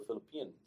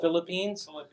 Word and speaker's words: Philippians: And then I open up the Bible to St. Paul's letter to the Philippians. I don't know. How do you pronounce it? The Philippines? – Philippians.